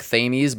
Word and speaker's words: Thames: There is the Thames River that is going through it, which is spelt like Thames, but it's Thames.